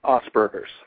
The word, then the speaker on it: Asperger's: So what is the difference of, am I pronouncing it right, is it Asperger's? – Asperger's. – Asperger's.